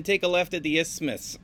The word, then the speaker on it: isthmus: Over this, and now this, here's a word I don't know how to pronounce, isthmus.